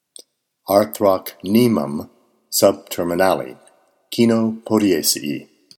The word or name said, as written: Arthrocnemum subterminale